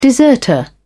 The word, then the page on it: deserter: British English